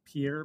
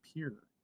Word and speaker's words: Pierre: It's spelled like Pierre, but we say Pierre. Like, take a long walk off a short pier.